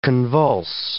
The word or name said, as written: convulse